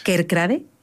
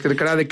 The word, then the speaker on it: Kerkrade: Los holandeses lo pronuncian de otra manera. – Sí. – Que está entre medias de Kerkrade y Kerkrade, es algo más abierto.